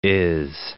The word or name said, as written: is